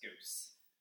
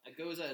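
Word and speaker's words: Gose: Goza is a German word. – Why isn't it pronounced goose? – Because it's a German word.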